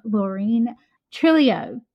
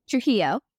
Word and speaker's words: Trujillo: So today I have the pleasure to welcome Laureen Trillio. Trujillo.